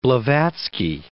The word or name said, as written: Blavatsky